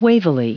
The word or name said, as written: wavily